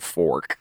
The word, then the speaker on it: 4K: You're supposed to pronounce it fork.